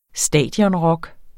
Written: stadionrock

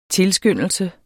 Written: tilskyndelse

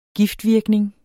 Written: giftvirkning

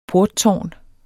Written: porttårn

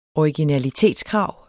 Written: originalitetskrav

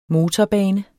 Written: motorbane

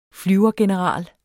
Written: flyvergeneral

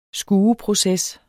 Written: skueproces

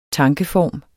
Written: tankeform

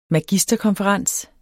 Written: magisterkonferens

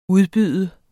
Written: UDBUDTE

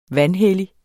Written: vanhellige

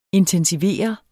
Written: intensivere

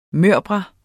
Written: mørbrad

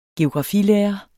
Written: geografi

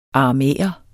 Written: aramæer

